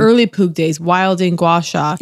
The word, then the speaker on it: gua sha: The gua sha, which I guess is actually pronounced gua sa.